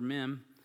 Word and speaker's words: Mem: So may our time in this stanza of Mem, this is the Hebrew letter Mem, and it's pronounced just like it's spelled in the Hebrew language as well.